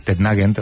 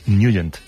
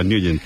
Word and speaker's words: Nugent: Siempre lo hemos llamado en el barrio Tednagent o Tednagent. – Claro, yo era de Tednagent. – Pero la pronunciación correcta es Nugent. – Te fuiste a Washington y descubriste que era Nugent.